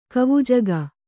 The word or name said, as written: ಕವುಜಗ